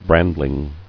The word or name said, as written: Brandling